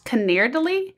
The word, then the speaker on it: canederli: So it's pronounced Canerdili. It's like C-A-N-E-D-E-R-L-I.